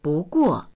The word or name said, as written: bu2guo4